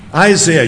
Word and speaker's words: Isaiah: Isaiah, you know the story of, or as it's properly pronounced, Isaiah, but I don't want to make you feel scared and so on.